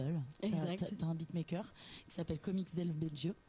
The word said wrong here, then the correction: Biagio: Del Biagio. – Del Biagio. – Ouais. Je le prononce Del Biagio.